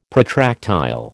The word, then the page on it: protractile: American English